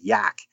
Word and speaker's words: Yaak: But there is one spot in northwestern Montana called the Yak, and it's spelled Y-A-A-K, and it's in the Yak River Valley.